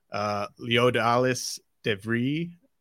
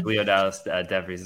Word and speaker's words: Leodalis De Vries: Leodalis Devry. Am I saying that right, do you think? – I'm not 100% sure, but yeah, I think Leodalis Devries is how I would say it.